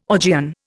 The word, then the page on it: Augean: Indian